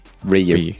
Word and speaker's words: Ryu: Because everyone knows Ken's better than Ryu. Oh, sorry, Ryu. Ryu. Sorry, that's like 30 years of saying his name wrong.